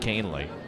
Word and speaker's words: Kahnle: K-A-H-N-L-E, pronounced Canely.